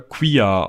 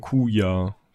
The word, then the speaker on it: Quia: Q-I-A? Äh, ja, ich, ich glaube schon, dass du das nicht äh, Quia aussprichst, sondern Kuya. Kuya.